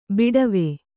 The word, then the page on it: ಬಾಡವ: kannada